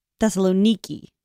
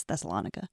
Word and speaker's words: Thessaloniki: Nowadays, I think you're supposed to pronounce it Thessaloniki. Thessalonico. – Mm-hmm. – But when you talk about it in like ancient context or like in biblical context, it's Thessalonica.